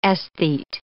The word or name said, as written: aesthete